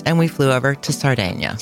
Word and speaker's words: Sardinia: See, so, first, I'd like to explain that that is the correct pronunciation of the island, Sardinia. That's Italian. Most English people speaking call it, pronounce it Sardinia. Sardinia. Mm-hmm. That's not right. No, so it's Sardinia. Sardinia.